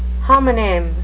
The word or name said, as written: homonym